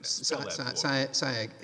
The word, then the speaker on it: Saieg: And spell Sy-Egg for me. – It is Sy-Egg. Yes, Your Honor. Sy-Egg, S-A-I-E-G, if I'm pronouncing it correctly.